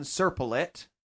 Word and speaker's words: Serpollet: That's pretty, this guy, he's a French dude, Leon Serpollet. That's how he pronounces it, not me. I looked it up. Serpollet, that's a French pronunciation.